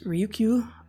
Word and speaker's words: Ryukyu: These are found off of the, I think it's pronounced Ryukyu Islands in Japan, between just north of Taiwan.